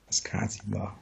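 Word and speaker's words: Ascacíbar: Ich tippe auf Askasiba. – Askasiba, okay. – Also da Betonung auf dem I eigentlich, also Okay.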